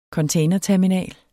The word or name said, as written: containerterminal